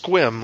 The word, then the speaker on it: Sequim: It's pronounced Squim, like somebody was trying, like Popeye was trying to say swim, but it's Sequim.